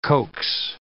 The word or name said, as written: coax